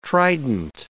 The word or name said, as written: trident